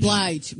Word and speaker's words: Blige: I can't even pronounce the name. Bl- Bliggy, Blidge. Blige. – Mary J. Blige.